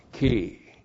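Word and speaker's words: ki (כִּי): K, long I, ki.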